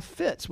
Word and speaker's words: Fitz: And because people get it wrong my whole life, I go by Fitz, which is short for Fitzgerald. But then still people say Fritz.